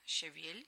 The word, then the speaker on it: щавель: Да, да, многие говорят «щавель» и «ревень», но на самом деле это «щавель» и «ревень». И вот в этих словах, когда мы начинаем склонять, у них ударение переносится на окончание не «щавеля», а «щавеля», не «ревеня», а «ревеня». Да?